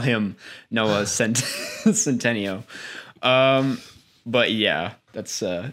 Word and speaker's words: Noah Centineo: Noah Centineo.